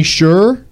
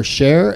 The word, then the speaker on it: Sher: Anthony Scher, or Scher, S-H-E-R is how it's spelled. – Either, it doesn't matter.